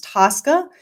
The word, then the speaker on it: TSCA: So the Toxic Substance Control Act, which is often, the acronym is often pronounced TSCA, is the premier chemicals management regulation in the United States.